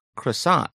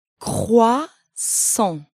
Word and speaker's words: croissant: Croissant. Wow, the pronunciation of that is harder than what I'm used to. Can you break that down for us one more time? – Croissant.